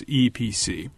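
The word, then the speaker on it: Eee PC: And they came out with this little laptop called the EEEPC, which they pronounce EPC.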